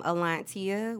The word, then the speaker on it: Alontia: And last but not least, my personal is my first name, Alontia, which is A-L-O-N-T-I-A, and Underrated is the end, no underscores, y'all.